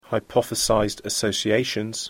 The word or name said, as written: hypothesized associations